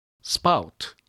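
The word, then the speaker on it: spout: もう一つ、雨どいという意味のスパウト。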